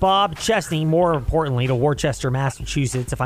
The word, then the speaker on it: Worcester: It seems like it, and I think they call it Worcester up there in Massachusetts.